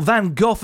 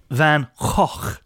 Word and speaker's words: Van Gogh: Or Van Gogh, as a lot of Brits say, but apparently yes, the proper way to say it is Van Gogh.